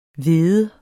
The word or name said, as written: hvede